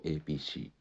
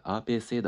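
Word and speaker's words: A.P.C.: 英語読みすると APC これ APC って発音していやいや APC じゃなくて RPC だか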